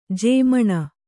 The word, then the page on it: ಜಮೀನು: jamīnu